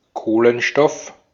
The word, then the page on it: carbon: /ˈkɑːr.bən/